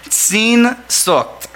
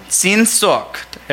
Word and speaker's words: Sehnsucht: but it's the word Sehnsucht. Sehnsucht.